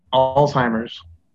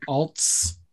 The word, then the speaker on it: Alzheimer's: Alzheimer's. – Like with a Z in there a little bit? Alzheimer's? – With very much a Z in there.